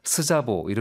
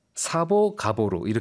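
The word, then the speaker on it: Szabó: 그 가보 사보 가보로 이렇게 얘기한다고 합니다. 반대로 얘기하기도 하고요. 이제 가보로 사보가 맞는 거죠. – 네. 그러니까 Z가 무음이군요, 여기서.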